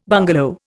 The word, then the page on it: bungalow: Indian